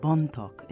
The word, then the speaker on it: Bontoc: Well, Bone Talk is set in a place called Bontoc. In fact, the title is how Americans mispronounced Bontoc when they came and invaded the Philippines in 1898.